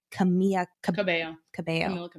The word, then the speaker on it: Camila Cabello: Artist of the Year, Cardi B, Billie Eilish, Ariana Grande, Halsey, Jonas Brothers, and Shawn Mendes, who is dating – Camila Cabello.